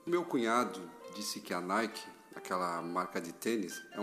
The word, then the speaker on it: Nike: A mesma grafia, só que a pronúncia não é a moda inglesa. Nike é a deusa da vitória.